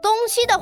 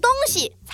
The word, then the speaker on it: 东西: 哦，我知道，“ 东西” 这个词念成“ 东西” 的话，就变成东西方向的意思了。要念成“ 东西” 才是物品的意思。